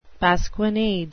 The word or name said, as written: pasquinade